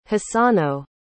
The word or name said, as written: hisano